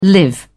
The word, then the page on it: live together: British English